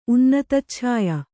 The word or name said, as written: ಉನ್ನತಚ್ಛಾಯ